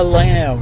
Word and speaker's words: lam: On the lamb. – On the lamb. – That's L-A-M. Just L-A-M. Pronounced exactly the same as the animal.